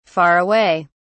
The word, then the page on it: far away: British English